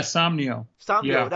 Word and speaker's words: Somnio: The Infinity Chamber, it was once called, I hope I'm saying this right, Somnio? – Yeah, Somnio.